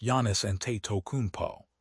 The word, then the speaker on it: Giannis Antetokounmpo: The Miami Heat are banking everything on elite talent like Giannis Antetokounmpo, pronounced Giannis Antetokounmpo.